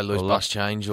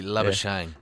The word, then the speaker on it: Labuschagne: Yeah, there's a few ways to pronounce it. I think we say loose bus change, or I think it's actually Labuschagne. – Yeah. Labuschagne, so. Yeah, South African name.